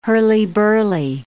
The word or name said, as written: hurly-burly